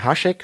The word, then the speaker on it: Hašek: Ich würde sagen, Hašek spricht man das aus, weil das ist so ein S mit so einem Apostroph. Und ja, das ist, das gibt's so eine Buchverfilmung.